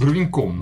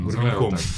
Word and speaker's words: Гурвинек: как я так и не, выучил, как правильно произносить. Гурвинком. Гурвинком.